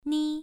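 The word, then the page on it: ni: tone 1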